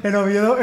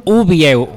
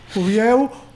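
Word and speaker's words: Uviéu: En Oviedo me tienen que odiar un poco, porque siempre lo digo mal. Siempre digo que son Oviedo. – Es Uvieu, lo sentimos. – Uvieu, ahora también acuñado por los jajas entre Noelia, Core 3, yo y más gente.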